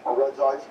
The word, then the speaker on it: Regice: I don't think it's pronounced Regice. – How's it pronounced then? – Regice, because there's only one eye.